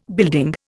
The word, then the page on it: building: Indian